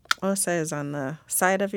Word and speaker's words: Xhosa: That includes the ones I'm aware of are Zulu and Xhosa, if I'm saying that right. So the name Xhosa is spelled K-H-O-S-A and X-H-O-S-A, and the X symbol indicates one of the clicks.